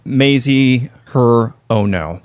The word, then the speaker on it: Hirono: Mazie Hirono.